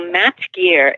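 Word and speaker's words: MAPT Gear: So Mapped Gear is spelled M-A-P-T Gear, G-E-A-R .com.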